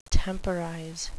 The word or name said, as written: temporize